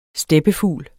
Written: steppefugl

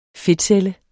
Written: fedtcelle

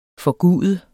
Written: forgude

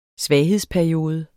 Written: svaghedsperiode

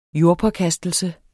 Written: jordpåkastelse

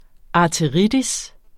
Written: arteritis

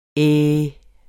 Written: æh

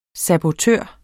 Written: sabotør

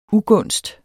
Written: ugunst

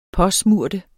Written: påsmøre

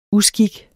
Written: uskik

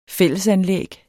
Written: fællesanlæg

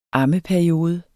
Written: ammeperiode

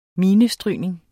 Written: minestrygning

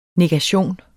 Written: negation

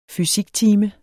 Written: fysiktime

